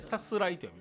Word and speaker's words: 出航: ね、出航と書いて出るにね、あの高校の方、誰か書いてくれてる。 – でさすらいと読みます。